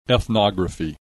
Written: ethnography

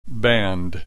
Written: band

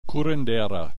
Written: curandera

